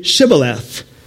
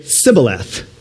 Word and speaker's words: Shibboleth: Then they would say to him, "Then say Shibboleth." And he would say, "Sibboleth." They couldn't say the SH, for he could not pronounce it right.